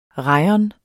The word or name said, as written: rayon